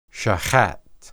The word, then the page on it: shahat: Hebrew